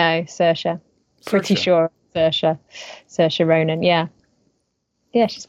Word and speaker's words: Saoirse: Yeah, Saoirse.